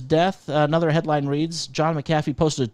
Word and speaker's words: McAfee: His name is pronounced McAfee.